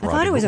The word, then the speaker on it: Rodriguez: Chi-Chi Rodriguez. – You would think, but... – It's pronounced Rodriguez.